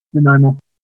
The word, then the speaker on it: Nanaimo: Nanaimo? Is that how you say it? Nanaimo. Vancouver Island.